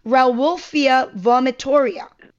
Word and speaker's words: Rauwolfia vomitoria: And the second one was Rauwolfia vomitoria.